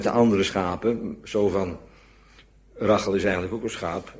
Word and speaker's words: Rachel: Want zo spreek je dat, mensen vragen dat, het is toch Rachel? Nee, het is Rachel. Hè? Dat is de gewone Nederlandse uitspraak, Rachel. Met die CA, of hebben we het daar al eens over gehad? Nee, hè? Rachel, ja. Kan het ook niet helpen, maar zo spreek je dat uit.